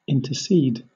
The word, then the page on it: intercede: British English